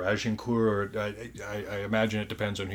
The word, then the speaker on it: Agincourt: So, the example I want to use of these five decisive factors is the Battle of Agincourt.